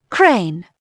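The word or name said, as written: crane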